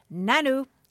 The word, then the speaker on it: Nanu: Upon his arrival at my mother's house one day, he looked at my mother, grinned wide, and uttered, 'Nanu,' yep, pronounced just like Mork would say it.